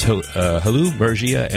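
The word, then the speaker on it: Hailu Mergia: And then before that, we heard from Halu Mergio. I'm not pronouncing that correctly. I apologize for that. It was Halu Mergia and the Dalak Band.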